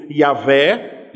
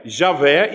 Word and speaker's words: Yahvé: Ele aparece aqui no terceiro mandamento duas vezes, e no hebraico é uma referência ao nome de Deus, que geralmente pronunciamos como sendo Yahvé, Javé, e alguns utilizam a nomenclatura Jeová.